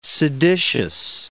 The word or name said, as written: seditious